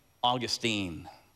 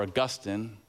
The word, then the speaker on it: Augustine: And so because it's a heart matter, I leave you with this final quote by St. Augustine, or Augustine, if you wanna pronounce his name that way.